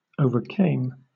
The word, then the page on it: overcame: British English